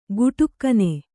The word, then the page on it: ಗುಟುಕ್ಕನೆ: guṭukkane